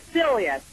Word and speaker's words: Celia: Celia, C-E-L-I-A. Okay, Celia. Well, I got V-I-L-I. Celia, C-E-L-I.